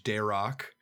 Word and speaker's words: Darroch: next up is George Darock, like Derek, but it's spelled D-A-R-R-O-C-H, and says one star, a very zero star experience.